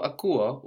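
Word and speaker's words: akuo (ακούω): So akuo also counts as a verb with its accent on the ending, even though we have uo there and the accent on the u.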